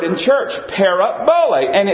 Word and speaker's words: parabole: And the Greek word is pronounced parabole. Parabole. Let's all say that together.